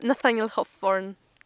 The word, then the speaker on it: Nathaniel Hawthorne: ale Nathaniel Hawthorne mi się wydaje, że jest ok.